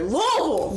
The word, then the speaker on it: Louisville: And you have to say it like it's one word. Louisville!